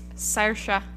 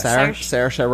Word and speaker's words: Saoirse: Saoirse. – Yeah. – Saoirse Ronan. – Saoirse yeah.